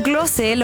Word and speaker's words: Klose: tras superar el récord que mantenía el alemán Miroslav Klose. ¿Qué tal mi pronunciación? Bien. Argentina 2, Austria 0. Ahora sí que sí, es una auténtica leyenda del fútbol oficial y certificado. Incluso el propio Klose lo felicitó públicamente y le dijo que es el mejor futbolista de todos los tiempos.